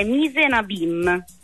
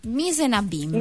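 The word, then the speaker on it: mise en abyme: Allora, la pronuncia esatta è Misenabim. – Misenabim.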